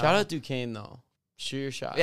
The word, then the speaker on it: Duquesne: It's that Duquesne thing.